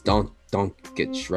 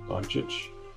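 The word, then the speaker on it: Dončić: How do you say Luka's last name correctly? It's Doncic, right? – Doncic. Doncic. That's what the announcers usually Doncic.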